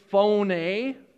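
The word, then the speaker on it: phone (φωνή): But in Greek, you'd actually pronounce it phone, phone, not like, not phony, like fake, phone, like the way a youper would say it, like, "Call me on the phone, eh?"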